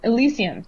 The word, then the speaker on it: Elysium: Elysium, that one.